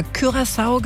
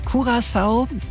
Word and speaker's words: Curaçao: Ich habe immer Curaçao gesagt, du sagst Curaçao. Wie ist denn jetzt die richtige Aussprache? – Für das Land Curaçao gibt es verschiedene Aussprachen. Keine Sprechweise ist dementsprechend falsch. Und da eigentlich alle neben Papiamento auch noch Niederländisch, Englisch und Spanisch sprechen, verstehen sie Curaçao, Curaçao, Curaçao, egal wie es ausgesprochen wird.